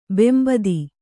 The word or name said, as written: ಬೆಂಬೂದಿ